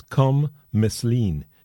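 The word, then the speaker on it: Kham Meslien: His name is Com Meslin. His first name is spelled K-H-A-M and then M-E-S-L-I-E-N, Com Meslin.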